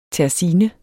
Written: terzine

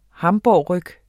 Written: hamburgerryg

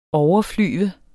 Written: overflyve

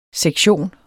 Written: SEKTIONENS

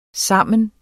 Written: sammen

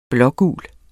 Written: blå-gul

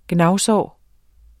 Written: gnavsår

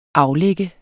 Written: aflagt

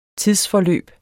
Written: tidsforløb

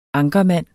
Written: ankermand

